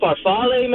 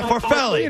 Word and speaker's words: farfalle: So I'm a chef, and I just heard say farfel. That's not a pasta, man. Are you talking about farfalle, man? – Yeah, farfalle. – You know what he meant. – I pronounce it wrong.